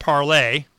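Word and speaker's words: Parler: Founded in 2018 in based in Henderson, Nevada, Parlor, pronounced Parlay. Yeah, say it's Parlay.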